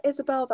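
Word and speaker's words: Ysabel: You can find me under at India Isabel.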